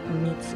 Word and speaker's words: myths: So when I say myths, I don't mean like myths, M-E. I think Cornell pronunciation is myths. It's not myths. It's myths. So it's M-Y. I'm talking about M-Y-T-H-S, not M-E-A-T-S.